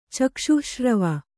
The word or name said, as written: ಚಕ್ಷುಃಶ್ರವ